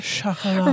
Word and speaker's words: Chocolat: I think we were a little tipsy, and I was like, it is not pronounced chocolat, it's chocolate. And you were like, no, bitch, it's fucking chocolat.